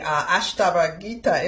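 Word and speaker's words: Ashtavakra Gita: They've come to the full fruition of the path, the Ashtava Gita, if I'm pronouncing that correctly. – You did a real good job. That was perfect.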